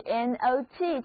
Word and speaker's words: knot: 最后一个关于 NOT 这个词根的单词是 K-N-O-T， Not，这里的字母 O 读作 O，而前面第一个字母 K 是不发音的， Not。